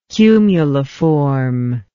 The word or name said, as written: cumuliform